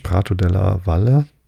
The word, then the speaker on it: Prato della Valle: Der große Platz, Prato de la Valle, hätte ich beinahe spanisch ausgesprochen, della Valle, nee, es ist italienisch, Prato della Valle, hoffe ich, genannt, ist ein sehr weiter Raum, wo der Hauptmarkt im Juni gehalten wird.